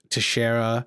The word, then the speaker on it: Teixeira: Jack Teixeira, who leaked...